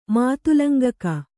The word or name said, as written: ಮಾತುಲಂಗಕ